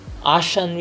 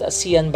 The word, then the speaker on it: ASEAN: If not Asia, at least within ASEAN region, ASEAN region. You know, I pronounced it ASEAN, but someone told me it's ASEAN region.